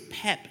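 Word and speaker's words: Pepê: Who knew it was Pep? It's not Pepe. – I had no idea it was Pep. – It's just Pep. – He even said it's he said Pep. In Brazil, like, he even qualified, said in Brazil, they call him Pep.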